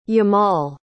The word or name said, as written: yamal